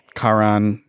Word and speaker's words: Charon: You can think of like Charon. Did I say that right? – Yeah. The ferryman.